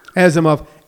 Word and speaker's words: Asimov: Asimov.